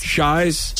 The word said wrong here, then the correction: CHI: It's Chi.